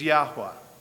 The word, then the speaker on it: Yahweh: God's name, the best we can figure out how to pronounce the Hebrew, is Yahweh.